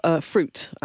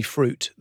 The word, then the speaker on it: Früt: Of Fruit, which is spelt F-R-U-T.